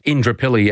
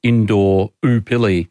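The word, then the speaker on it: Indooroopilly: I loved it when they pronounced Indrapilly as Indoor Oopilly.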